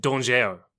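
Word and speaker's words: Dangeau: Yeah, spelt danger. – Of course it is. – But pronounced Dongeau because he doesn't play by your rules.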